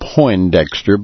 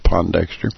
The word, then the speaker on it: Pondexter: I am in studio here on this Tuesday night because of Willie Earl Pondexter, and other news media mispronouncing his name, calling him Poindexter, but there's no I in Pondexter, and it's pronounced Pondexter.